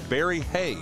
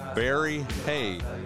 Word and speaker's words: Barry Hay: Well, this is the lead vocalist, Barry Hay. – There you go. – Barry Hay.